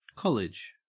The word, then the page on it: college: Australian English